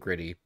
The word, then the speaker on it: Gritty: It's two T's, but it's pronounced with two D's. If you're from Philly, it gritty instead of grit gritty, it's, it's gritty.